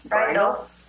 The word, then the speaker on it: Brindel: Hannah Brindle Berkovitz. – Brindle? – Brindle. – Brindle. – Is that Brindle? – B-R-I-N-D-E-L.